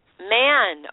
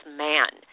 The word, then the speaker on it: Mahon: Is it Mahon? – It's pronounced Man. – Man. Okay, so it's spelled M-A-H-O-N, everyone, and it's Man.